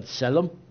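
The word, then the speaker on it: tselem (צלם): We're dealing with a Hebrew word called tselem, or tselem. There's a T in there that actually gets pronounced that my English lips have a hard time with.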